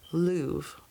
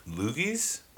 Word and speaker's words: Lugh: Anyway, it means the assembly of Lug, whatever. But that was the first harvest festival. – Lugis? – Lugh. How would you pronounce that in Celtic? – Lug.